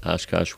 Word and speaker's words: Oshkosh: But we're going to be talking about, because in June in Oshkosh, Wisconsin, yes, that is how you say it, Oshkosh, Wisconsin, June 11th, 12th, 13th, and 14th, just in the morning on the 14th.